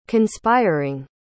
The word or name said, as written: conspiring